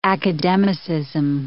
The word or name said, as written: academicism